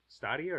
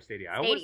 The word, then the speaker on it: Stadia: Is it Stadia or Stadia? – Stadia. – Okay, because I always want to call it Stadia because... – Because of the way it's spelled. – Well, not just that, because it's Italian in nature, and Italian, like, that's the Italian word for stadium, and you say stadia.